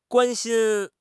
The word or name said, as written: guan1 xin1